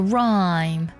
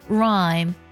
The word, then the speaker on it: rhyme: 另外呢，我们要特别的注意，当 H 在 R 后面的时候也是不发音的，比如说韵律这个单词读作 rhyme， rhyme 拼作 R-H-Y-M-E rhyme， rhyme。